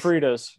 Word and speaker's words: Sofritas: So one has Sofritas. I don't know what that is. Probably butchered the pronouncing. Brown rice. – Sofritas. Yeah, I used to work at Chipotle.